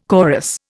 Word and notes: chorus: Indian

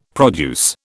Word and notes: produce: British English

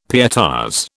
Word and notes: pietas: British English

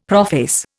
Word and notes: prophase: Indian

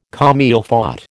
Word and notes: comme il faut: American English

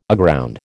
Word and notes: aground: American English

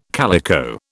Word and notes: calico: British English

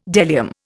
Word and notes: bdellium: Indian

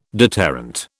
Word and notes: deterrent: British English

